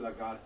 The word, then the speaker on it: Lagarde: Of course, much of what was said fitted in what Christine Lagarde said, and that's how you say her name.